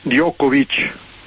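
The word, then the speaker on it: Đoković: Pantic, Djokovic,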